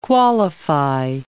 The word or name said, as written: qualify